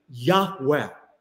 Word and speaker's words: Yahweh: It's composed of the four Hebrew letters, Yud, He, Vav, He, which most Semitic language scholars, ancient Semitic language scholars, believe is pronounced a breathy Yahweh.